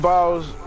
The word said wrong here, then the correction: Biles: Oh, I'm sorry. I, I did not mean to mispronounce a name like that. I said Simon too. Simone Biles.